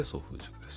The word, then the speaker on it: 創風塾: うとする人たちがそれぞれのビジョンを描きまたそのための戦略を作り実践する場として総風塾を、えー、主催しています、えー、創造の層に、えー、クリエイトですねクリエイトの層に風と書いて総風塾です、